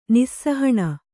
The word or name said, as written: ನಿಸ್ಸಾಹಣ